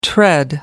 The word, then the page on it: tread: American English